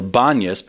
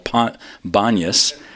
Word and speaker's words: Banias: And today the place is called Panias from the god Pan, or Banias, because Arabs can't pronounce the P, so it's now called Banias.